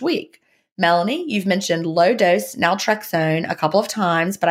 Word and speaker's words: naltrexone: The subject is question about naltrexone. Is that how you say it? Naltrexone? – Mm-hmm.